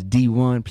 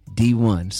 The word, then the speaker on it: Dee-1: So, ladies and gentlemen, my name is Dee-1. Please spell it right. It's D E E dash the number one. You hear me? Dee-1.